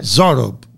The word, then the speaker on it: Zorob: He's a photographer, lives in the Noblesville area, and he's connected to an incredible minister of the gospel in Lebanon named Joseph Zorbo. Did I say that last name right? Zorbo, is that how you say it? – Zorob. – Joseph Zorob.